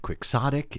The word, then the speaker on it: quixotic: He says the term originates from Don Quixote, so the pronunciation is Quixotic.